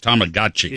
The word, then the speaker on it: Tamagotchi: But it was called Tamagotchi. – Okay. – It was called a Tamagotchi. – Tamagotchi. – You're close. I'll give it to you. It's Tamagotchi.